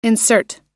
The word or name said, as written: insert